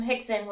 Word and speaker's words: Rickson: And so we spelled his name the same way, R-I-C-K-S-O-N. And I would always say, why are we calling him Hickson when it's spelled Rickson? And he's like, it's Brazilian.